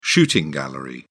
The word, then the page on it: shooting gallery: British English